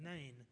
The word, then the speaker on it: Nain: a city called Nain, N-A-I-N, Nain. That city's still there today, but it's called N-E-I-N, Nain, I guess how you pronounce that.